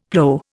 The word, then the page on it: plow: Indian